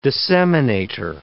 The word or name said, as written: disseminator